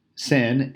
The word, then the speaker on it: Sennacherib: it's pronounced Sin. Sin and Cherub.